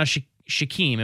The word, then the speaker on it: Shaquem: Now Shaquem, am I pronouncing that right? – I believe you're correct.